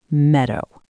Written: meadow

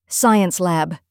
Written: science lab

